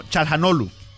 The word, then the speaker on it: Çalhanoğlu: Y ellos, por ejemplo, Çalhanoğlu, él lo dice como Çalhanoğlu. No pronuncian la G. Y por ejemplo, la C se pronuncia como CH o como casi como J.